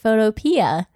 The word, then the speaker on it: Photopea: Bubba pronounces it Photopea. I think if it were spelled photo and then like the P part or P I A, like encyclopedia.